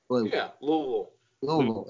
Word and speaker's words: Louisville: Louisville. – It's like one syllable.